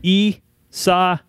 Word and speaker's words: Isaias: All right, we should pray for the people that were impacted by hurricane or tropical storm Isaias. – How do you say that? I'm going to do this phonetically here. – Isaias.